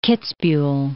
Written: Kitzbuhel

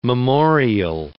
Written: memorial

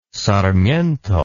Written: sarmiento

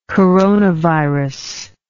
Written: coronavirus

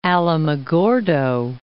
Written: Alamogordo

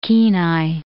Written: Kenai Peninsula

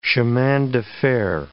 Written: chemin de fer

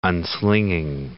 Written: unslinging

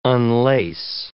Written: unlace

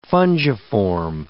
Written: fungiform

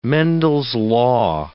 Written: Mendel's law

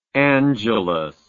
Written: angeles

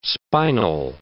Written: spinal

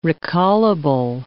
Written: recallable

